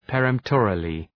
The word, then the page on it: peremptorily: pə’remptərılı